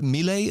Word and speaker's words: Milei: Milei.